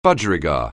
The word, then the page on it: budgerigar: anglais